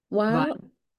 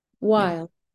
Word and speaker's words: Weil: Weil. – Again? – Weil.